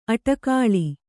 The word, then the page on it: ಅಟಕಾಳಿ: aṭakāḷi